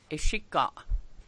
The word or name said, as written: Come In